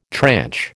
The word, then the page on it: tranche: American English